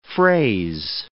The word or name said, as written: phrase